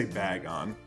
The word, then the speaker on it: Bagon: I'm gonna say Bagon. – Final answer. Bagon is correct.